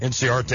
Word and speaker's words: Inciarte: And, of course, Colton Wong, a guy that is suffering basically from the same injury that is it in, how do you say the kid's name for the Atlanta that's not going to play in the first series? Their normal center fielder. – Ender Inciarte? – Yeah, that's Inciarte.